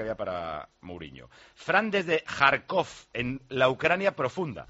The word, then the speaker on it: Kharkov: Dice, parecerá increíble, pero en las calles de Kharkov se ven casi tantas camisetas de la selección española como tías buenas, y de estas hay unas cuantas. – Kharkov, qué bien pronunciado. Kharkov o Kharkiv.